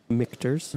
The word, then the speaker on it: Michter's: Now, Michter's, is that how you say it? – Michter's.